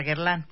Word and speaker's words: Guerlain: ¿Se dice bien? ¿Lo dije bien? Sí, es correcto. Guerlain.